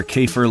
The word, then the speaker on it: Kafer: You are. Kafer like wafer.